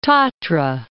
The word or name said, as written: Tatra